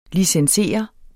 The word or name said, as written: licenser